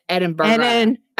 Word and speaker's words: Edinburgh: Edinburgh. Edinburgh. Yeah. – Yeah, so I can't do it. – You have to add an extra syllable in there at the end.